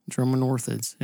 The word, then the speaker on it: Dromornithids: This one is a family of extinct birds known as, if I'm saying this right, Dromornithids? – Dromornithids, yes.